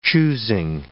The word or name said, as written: choosing